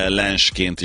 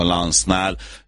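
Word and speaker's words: Lens: Hát hallottam már Lensként is említeni, de maradjunk a Lansznál, és akkor gyorsan öntsünk tiszta vizet a pohárba.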